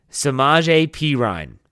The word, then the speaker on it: Samaje Perine: Let's stop Samaje Perine is how you say it.